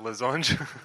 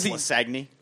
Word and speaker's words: lasagne: and he used to pronounce lasagna, lasange. – Lasange. – Oh, man. – Yeah, we all know it's lasagne.